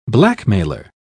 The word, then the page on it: blackmailer: British English